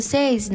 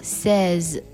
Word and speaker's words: says: 不少人看到加了 s 之后呢，是不是读作 says 呢？其实变三单的时候，它会变作 a 的音，读作 says。